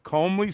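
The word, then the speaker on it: comely: I guess Homely rhymes with Comley, so people don't say Comley.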